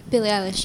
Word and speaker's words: Billie Eilish: I know you've got a single or a video out that is Billie Eilish? – Billie Eilish, yes.